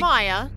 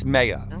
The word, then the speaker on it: Maia: Or Greek, Maya. – Yes, but the Greek pronunciation in this... – Is it? – It's Maia for this, all right? – Now I have to question everything.